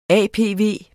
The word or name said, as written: APV